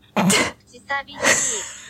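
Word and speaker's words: Kuchisabishi (口寂しい): So hätte ich es, glaube ich, auch ausgesprochen. Ja, sprich mal aus. Oder Kushisabishi. Könnte auch sein, da bin ich jetzt echt gespannt. Kuchisabishi. – Nochmal? Kuchisabishi. – Kuchisabishi. – Ja, so ungefähr.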